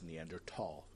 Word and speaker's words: Neanderthal: Not Thal. They didn't pronounce that. It's Neanderthal. It's the correct pronunciation.